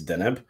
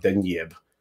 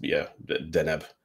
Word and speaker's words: Deneb: Honestly, if you want to get pedantic about it, it's Deneb. But I say all kinds of different things because it depends which, you know, going back to how excited we are in conversation, sometimes I enunciate the wrong syllables. – Yeah. – I kind of like that Jeff had the funny accent over the end. So it would be like Denyeb. – Denyeb. – Which I thought was quite good. – Yeah, I can live with that. It's a happy compromise. – But no, it's technically just, Deneb.